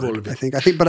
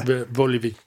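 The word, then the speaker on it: Vulović: It's a ch sound at the end of the word, Vulovic. I think it might be the y. – Vulovic.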